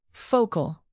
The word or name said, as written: focal